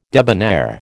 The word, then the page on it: debonair: American English